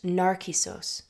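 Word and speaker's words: Narcissos (Νάρκισσος): In Greek, Narcissus is pronounced Narcissos, and as a word, it shares the same root as the Greek word narcotic, narke, which means to numb.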